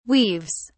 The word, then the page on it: weaves: British English